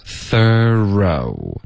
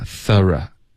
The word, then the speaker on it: thorough: OK, 所以第一个 thorough, 这是一个美式的发音 OK, 第二个 thorough,